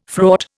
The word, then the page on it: fraught: Indian